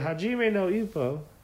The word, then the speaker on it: Hajime no Ippo: But it's a Japanese anime called, and this is in Japanese, so, you know, not a native speaker at all, but we're pretty sure it's pronounced Hajime no Ippo.